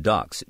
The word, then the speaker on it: DUX: And then for a while there was one, it's spelled D-U-X, but pronounced ducks, D-U-C-K-S.